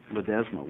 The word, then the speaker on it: Ledesma: If she would come forward, because I can't read your last name. – Ledesma.